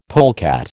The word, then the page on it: polecat: American English